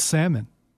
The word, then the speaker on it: salmon: It's salmon. The L is silent.